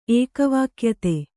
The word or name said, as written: ಏಕವಾಕ್ಯತೆ